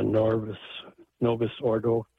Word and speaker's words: Novus Ordo: And I believe it's because they think it's more reverent than the Novus Ordo, if I pronounce that right.